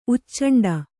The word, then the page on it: ಉಚ್ಚಂಡ: uccaṇḍa